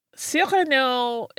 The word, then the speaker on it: Cyrano: We talked about Cyrano de Bergerac this week. We did. I didn't pronounce it that way at all, though. – I didn't in the episode because it's Cyrano.